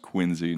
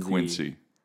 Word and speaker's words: Quincy: Quincy Adams, pronounced Quincy, not Quincy. Why is that? – Because that's how the Quincy family pronounced it.